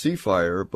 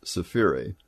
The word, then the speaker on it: Sefire: And Sefiri is S-E-F-I-R-E. It looks like it should be pronounced Seafire, but it's Sefiri.